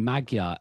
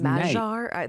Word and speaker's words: Magyar: But aside, his name, Magyar, and that's the English pronunciation. It will be slightly different in Hungarian. – I think it's Magyar.